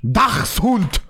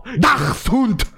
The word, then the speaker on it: Dachshund: Dachshund. Dachshund.